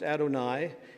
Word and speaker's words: Adonai: Uh, that's Adonai, usually, as it is uh, pronounced, Adonai.